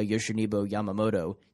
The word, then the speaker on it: Yoshinobu Yamamoto: Yoshinobu Yamamoto, the top pitching prospect from Japan.